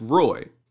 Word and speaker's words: Roy: But like I would like that's the pronunciation of my name to me is Roy.